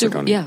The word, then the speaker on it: Fredonia: Thank you for pronouncing it, because most people probably wouldn't know. I said Fredonia. You're at Fredonia. – Oh, okay. – It's Fredonia. Fredonia.